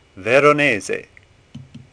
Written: Veronese

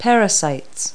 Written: parasite

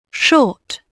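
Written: shoes

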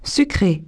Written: sucres

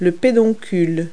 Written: pedicel

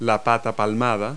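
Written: webbed foot

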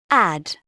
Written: add

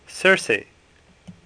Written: Circe

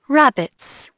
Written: rabbits

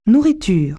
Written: food

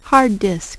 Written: hard disk

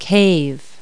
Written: cave

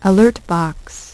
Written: Alert box

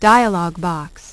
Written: dialog box

